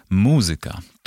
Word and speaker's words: muzyka: Jak pisze PWN, w starannej wymowie wymienione wyrazy należy akcentować na trzeciej sylabie od końca. Zatem matematyka, fizyka, pedagogika, muzyka.